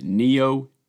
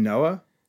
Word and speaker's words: Nioh: We'll start off with Nioh 2. – You mean Noah? – No, I pronounced it right.